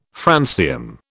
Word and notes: francium: British English

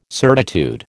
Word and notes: certitude: American English

